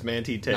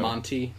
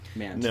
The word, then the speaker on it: Manti Te'o: That player, whose name I don't know how to pronounce properly, is Manti Te'o. – Sure. – Yeah. I believe it's, the general consensus is Manti Te'o. – Not Monty. Manti. – No. – Okay. – Yeah. – Manti Te'o.